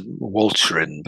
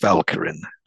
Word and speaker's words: Walcheren: It's spelt, people might know it as Walcheren, but it's pronounced Walcheren.